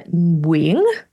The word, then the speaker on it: Nguyen: I am Amanda Nguyen. That's how you pronounce my last name.